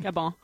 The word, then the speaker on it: Gabon: Is that how you pronounce it? Gabon or Gabon? – Gabon. – Gabon. Oh, so it's like, it's en français.